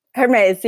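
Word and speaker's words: Hermès: And she's appeared in advertisements for, I'm going to say it wrong, because I'm so bad. Hermes? – Hermes, yeah. – Okay, good.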